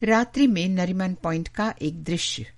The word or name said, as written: नरीमन पॉइंट